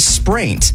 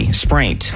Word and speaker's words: spraint: What is spraint? S-P-R-A-I-N-T. Spraint.